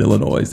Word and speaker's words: Illinois: It's not even Illinois. The S is silent.